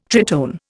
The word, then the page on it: tritone: Indian